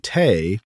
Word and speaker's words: thé: The French language differentiates between un thé, rhymes with bay, made with Camellia sinensis, and une tisane, made with other plants.